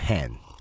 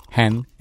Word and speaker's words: 한: 제목이 헨이에요. 헨. 한 H A N. – 음. 네. – 왜냐하면 거기 주인공들은 그 단어를 한이라고 읽거든요.